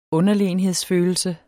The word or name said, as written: UNDERLEGNE